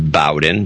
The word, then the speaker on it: Bowden: Cody Brown in Bowden, Alberta, and I believe it's pronounced Bowden, B-O-W-D-E-N. – Sounds right.